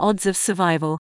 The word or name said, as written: odds of survival